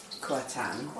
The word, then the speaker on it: Kuantan: And were I to travel to, I'm going to say it wrong, Kuantan. – Yes.